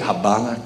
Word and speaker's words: Havana: In Havana, is that how you say it? Havana, Cuba.